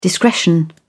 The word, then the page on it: discretion: British English